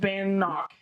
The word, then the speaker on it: Bannock: Well, that's not how you pronounce it. Bannock. – Bannock.